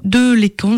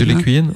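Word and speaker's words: de Lesquen: Alors comme j'écoute pas Radio Courtoisie, je ne sais jamais s'il faut dire Henri Delecq ou de Lécan, je crois que c'est de Lécan. – De Lécuyenne, hein. Ouais, de Lécuyenne, c'est ça.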